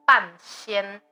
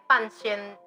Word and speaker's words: Ban Xian: The keyword, you What's Up Taiwan, or the name of the film is Ban Xian. – Mm-hmm. – And Ban Xian is the pronunciation comes from pinyin system.